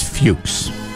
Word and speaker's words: Fuchs: It's Fuchs. – I believe it's Fuchs. – That's what people who have that name pronounce it as.